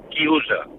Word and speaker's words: chiusa: Y un momento, y quiero decirle a Fernando que en italiano se dice chiusa.